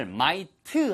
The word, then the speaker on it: might: 발음 하나만 주의하세요. M-I-G-H-T의 발음을 might 하고 을 붙이시면 안 됩니다. 영어에는 을라는 소리가 없어요. 한국어의 을 소리는 되게 음가가 강하거든요. 영어에서 이렇게 슬쩍 지나가는 듯한 을하고는 음가가 달라요. 사운드 밸류가 다릅니다.